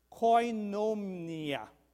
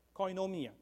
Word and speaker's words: koinonia: Now, the word fellowship comes from the Greek word, and I'm going to try to pronounce this, koinonia, okay, koinonia, which means a partnership.